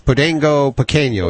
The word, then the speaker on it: Podengo Pequeno: And then you had the Portuguese Podengo Pequeno. Is that how you say it? – That's right. Good for you.